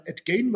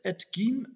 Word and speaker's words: Gein: Oder die Deutschen ja. sagen Ed Gain, weil das so für uns so geschrieben wird. Aber Ed Gein wurde ausgesprochen ähm, auf dem basiert, äh, wie man äh, weiß, Norman Bates, einer der berühmteste Psychopathenfilm, nämlich Psycho.